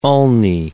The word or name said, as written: ulnae